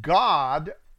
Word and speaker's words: Gad: And spoke Yahweh unto God, G-A-D, that's a long A, God, not Gad, God, G-A-D.